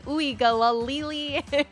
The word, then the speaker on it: Uiagalelei: they finally pulled out a pronunciation out of him, and it was like, ooey, galalili.